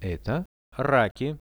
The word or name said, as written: eto raki